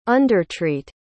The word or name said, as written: undertreat